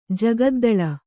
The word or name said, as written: ಜಗದ್ದಳ